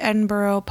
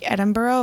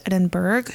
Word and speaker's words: Edinburgh: Now, this scale is from Great Britain, so it's pronounced like Edinburgh versus Edinburgh. So for any of my Pittsburgh people, this is pronounced like the town of Edinburgh in PA, which borough is actually spelled borough, unlike this one.